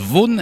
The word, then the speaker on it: Vaughan: voilà, ça, ça s'écrit Vaughan, mais ça se prononce Vaughan. et eh bien, oui, c'est un peu sournois.